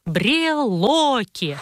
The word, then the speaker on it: брелоки: Вы сейчас очень удивитесь, потому что правильно говорить «брелоки». Один брелок, а много брелоков. Это «о» ударное, поэтому сбежать, как делают некоторые гласные, не может.